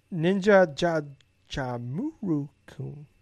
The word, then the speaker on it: Jajamaru-kun: Now, as for the NES game, like Ninja Jajamaru-kun, I mean, it's like I No, Gables, you gotta say it like I said it. – what? – Once let me pull it back up here so I can read it properly. Ninja Jajamaru-kun. That's the proper way to pronounce it.